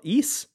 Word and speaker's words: Ys: There are a lot of people in the Discord talking about the, I think it's called Ys, is how you pronounce the game, but it's YS.